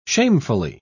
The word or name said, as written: shamefully